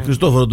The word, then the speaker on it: Χριστόφορο: Αλλά το Χριστοφόρος τονίζεται ως ονομαστική, ως όνομα, είναι λίγο μια, πώς θα το πω, επινόηση. Ας πούμε. Ναι. Χριστόφορο τον λέγαμε, αφήστε τον Χριστόφορο, καλά είναι μια χαρά το παιδί.